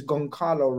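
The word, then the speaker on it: Gonçalo: So I believe, unless someone corrects us, it's Goncalo Ramos.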